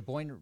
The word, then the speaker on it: Boyne: Can you talk about that little, is it the Boyne River Research Project? Is that correct? Did I pronounce that right? – Yep. – Yeah, so the Boyne River Research Project.